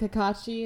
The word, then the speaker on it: Kakashi: Also, we looked up Kakashi, is that how you pronounce it? – Yeah.